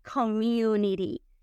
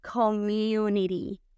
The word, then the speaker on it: community: La vemos escrita, se escribe community, pero a la hora de pronunciar, vamos a pronunciarla la T suavecita, community, community.